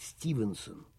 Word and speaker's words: Стивенсон: 9 июня 1781 года, 215 лет назад, в крохотном городке Уайлем в Нортумберленде родился гениальный механик-самоучка Джордж Стивенсон, правильнее произносить Стивенсон, создатель первой в мире железной дороги между Стоктоном и Дарлингтоном, а затем между Ливерпулем и Манчестером.